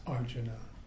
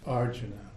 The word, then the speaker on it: Arjuna: The leader of the forces of good is named Arjuna, Prince I've always mispronounced that. It's Arjuna. Arjuna.